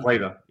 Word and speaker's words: Bleda: Well, conversely, and this almost sounded Shakespearean when I read it, Attila the Hun had a brother, and I'm probably going to mispronounce this, Bleda, B-L-E-D. – Yeah, Bleda, yeah.